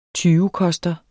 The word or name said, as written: tyvekoster